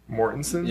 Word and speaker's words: Mortensen: Is that how you say it? Mortensen? – Yeah.